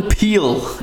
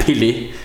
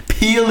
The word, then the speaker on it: Peele: Øh, han hedder Jordan Peele. Ja. Ikke Pelé. Peele. Peele. Peely Peel, tror jeg. Jeg tror bare, det udtales Peel. Det staves øh, P-E-E-L-E.